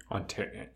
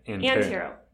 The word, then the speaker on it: Antero: Antero. Antero. Antero. Antero. Yeah, Antero. like an ant. Like an ant. I said it wrong.